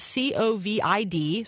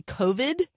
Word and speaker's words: COVID: It is COVID. So I'm going to say COVID, COVID, COVID-19.